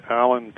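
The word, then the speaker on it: Allan: Well, the easiest thing is just to Google my name, which is Alan Combs, and that's spelled with two L's and two A's, so it's A-L-L-A-N.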